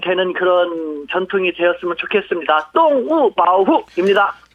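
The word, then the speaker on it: 똥우 (动物保护): 발음도 동물은 둘 다 사성입니다. 똥우, 똥우 하고 보호는요. 삼성, 사성, 바후, 바후 해서 동물보호 하면은 똥우, 바후, 똥우, 바후.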